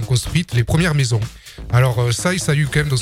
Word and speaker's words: Saix: Le Tarn à l'honneur ce matin avec la commune de Saïs. – Oui, Saïs, et non pas sexe, comme certains visiteurs le prononcent. – Évidemment. – Ça s'écrit S-A-I-X, mais se prononce Saïs.